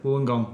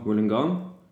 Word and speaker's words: Wollongong: Wollongong. Wollongong.